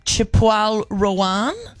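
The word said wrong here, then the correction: Chappell Roan: That's pronounced Chapel Roan.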